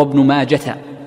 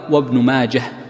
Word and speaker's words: ابن ماجه: أيضا من الفوائد تتعلق بهذه الجملة من كلام المصنف قوله ابن ماجه، والمشهور عند المحدثين أن الهاء في ابن ماجه هاء ساكنة، وكثير من الناس يجعلها تاء مربوطة فيقول روى ابن ماجة، وهذا المحدثون في نطقهم لا يصححونه، لا يصححون هذا الشيء.